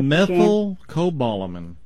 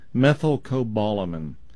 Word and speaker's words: methylcobalamin: Methyl sounds like ethyl, as in ethyl Mertz, but it's with an M on the front. Methyl is M-E-T-H-Y-L. – Yes. – And then cobalamin. That's going to be C-O-B-A-L, I think, I-M-N, sorry, methylcobalamin.